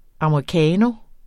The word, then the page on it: americano: ɑmɑiˈkɑːno; ɑmɑiˈkæːno